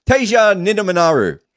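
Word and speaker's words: Teja Nidamanuru: Teja Nidam. Oh my God, I still can't do it. Teja Nidamanaru.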